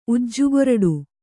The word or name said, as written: ಉಜ್ಜುಗೊರಡು